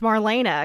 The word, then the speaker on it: Marlene: I guess that's pronounced Marlena, because everyone's saying it Marlena, but it looks like Marlene.